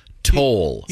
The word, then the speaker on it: Sithole: So you're suggesting that it's the you divide the syllable after the I's. – Yeah. – Like, so he had sitole.